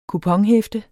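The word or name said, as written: kuponhæfte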